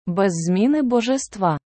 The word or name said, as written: без зміни Божества